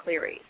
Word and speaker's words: Cleary: I am Claudia Cleary on Facebook. It's spelled exactly as it sounds. Claudia Cleary, C-L-E-A-R-Y. One L, my last name. One L, my first name.